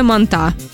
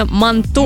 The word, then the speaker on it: манту: Ее очень сильно удивляет, когда приходят и говорят, мы на прививку реакция «манта». – Манта? – Ну, правильно, реакция манту.